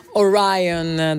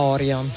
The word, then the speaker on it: Orion: Ci siamo appena goduti questi 8 minuti e passa di Orion dei Metallica. Questa è la pronuncia corretta, però raga, io quando l'ascoltavo con la mia band era Orion, cioè un po' come gli U2, i Rem, quelle robe lì, e quindi il sentimento mi ha portato a quando ero una giovincella che si godeva questo pezzo sul furgone con la band.